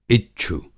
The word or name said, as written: इच्छु